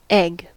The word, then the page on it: egg: American English